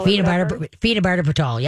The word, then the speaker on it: phenobarbital: Um, I'm going to say it wrong. Fernabarbital. – Phenobarbital. Yep.